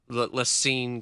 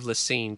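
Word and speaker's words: La Cygne: Lacine, Kansas? That's the first time I've even heard of Lacine, Kansas. And they think they're fancy, too, because it's spelled with a C-Y. C-Y-N-G-N-E. – It's L-A space C-Y-G-N-E.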